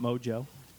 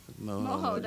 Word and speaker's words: Mojo: Are you sure it's not Mojo? – I don't know. The Mojo one's pretty good, man. – But you said Moho. – Moho, that's how you say it. It's Puerto Rican.